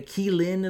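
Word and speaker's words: Qilin: So, um, the uh, uh Kilin is also what it's pronounced, and and Kirin is specifically the Japanese um, name of it, and and in Japan it is very it's typically like a unicorn.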